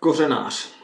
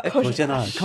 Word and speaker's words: Kořenář: How do you pronounce that name? – Josef Kořenář. – See, Kořenář. – There's not a lot of S's in a name that doesn't have an S. – An emphasis on certain syllables, too, that we always get wrong.